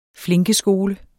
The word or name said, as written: flinkeskole